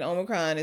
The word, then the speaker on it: Omicron: I mean, people are like, oh, yeah, people who are getting Omicron is not as...